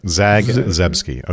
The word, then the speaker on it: Zagzebski: Is that right? Zagzebski. – That is a phonetic pronunciation. – Take them apart. Zagzebski.